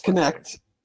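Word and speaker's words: Knecht: Dalton Connect, is that his name? – Yes. – Connect. I don't know how to pronounce it. – Yes, Connect. Mm-hmm. Yes. – Like Connect Four? That's how you pronounce it? – Yeah. – K-E-N-E-C-H-T or something like that? – K-N-E-C-T.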